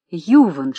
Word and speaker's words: Юван: Иван Шесталов значится в литературе как Юван Шесталов, к тому же с ударением на букве «Ю», хотя в писательских справочниках помечено в скобках и уточнено, что все же он Иван Николаевич.